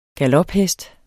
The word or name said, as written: galophest